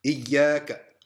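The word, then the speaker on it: Iyaka (إِيَّاكَ): Kaf, Fatha. Ka. Kaf and a Fatha equals Ka. Iyaka.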